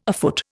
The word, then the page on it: afoot: Indian